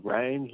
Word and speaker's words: Reyne: My real name's Rain, like as in the weather, you know, like rain. Yeah, so I don't know why, like, you know, it's weird. It's spelled R-E-Y-N-E,